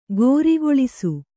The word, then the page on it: ಕರಿಗೊಳಿಸು: karigoḷisu; Kannada